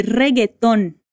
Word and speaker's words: reggaeton: Ela diz: E yo de reggaeton me encanta ver a mis nietos bailándolo. Me fala, de que a Eva gostaria de fazer aula? De reggaeton. Atenção à pronúncia do ritmo, de novo. De reggaeton.